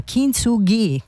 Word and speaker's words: Kintsugi: Elisa con Kintsugi, così è la pronuncia corretta della frase, della parola giapponese appunto con cui si usa per rappresentare,